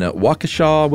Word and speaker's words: Waukesha: I'm a systems engineer in Waukesha, Wisconsin.